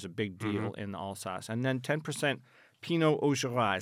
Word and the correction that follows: Auxerrois: Is that how you pronounce that? – Auxerrois. – Auxerrois? Okay, I wasn't sure whether the X was soft or hard.